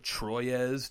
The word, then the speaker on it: Troyes: and that's Troyes, or it's spelled Troyes, T-R-O-Y-E-S, but it's Troyes by Z-Man.